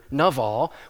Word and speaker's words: Nabal: How many of you have read 1 Samuel 25 and know the story of, we usually pronounce his name Nabal, right? Nabal is actually the Hebrew word, it's a transliteration of the Hebrew word Naval, which means fool.